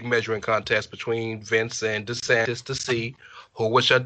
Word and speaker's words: DeSantis: Is that how you say his name? DeSantis? – DeSantis.